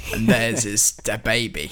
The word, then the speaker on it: DaBaby: I think I'm saying it right. It's DaBaby, DaBaby. Yeah. Although it's there's no space, so it's DaBaby.